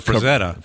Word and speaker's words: Frazetta: The Frazetta.